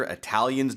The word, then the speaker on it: Italia: English speakers say Italy, the way we pronounce it, what are you saying? Italia, like the way Koreans say it, the way it's said in Italian.